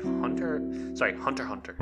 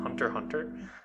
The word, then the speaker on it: Hunter x Hunter: Like, Hunter Hunter. I know the X is silent, so Hunter Hunter is what made My Hero Academia.